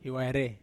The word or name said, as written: hiwaire